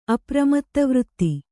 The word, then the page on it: ಅಪ್ರಮತ್ತವೃತ್ತಿ: kannada